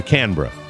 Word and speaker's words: Canberra: That should get you close to the proper pronunciation. Canberra or Canberra.